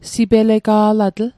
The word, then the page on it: sipelega'latl: Mi'gmaq